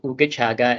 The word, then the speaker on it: Ooogachaga: So Ooogachaga is spelled as it is pronounced. So it's Ooogachaga, ugachaga.lgbt.